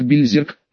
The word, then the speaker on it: Бельзерк: Название страны Айрис пишется и читается, как Бельзерк, но если убрать озвончение двух последних глухих согласных, то получится Берсерк, что как бы намекает.